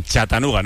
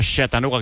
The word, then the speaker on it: Chattanooga: En Chattanooga, ¿no? No Chattanooga, que decía hoy Paco Jó. – Yo digo Chattanooga, pero sí. Si Paco Jó tiene más arte para pronunciarlo, pues fantástico. Pues vamos a dejarlo en Chattanooga.